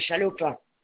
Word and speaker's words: Chalopin: D'accord, je suis madame Chalopin. – Ça s'écrit comment? – C-H-A-L-O-P-I-N.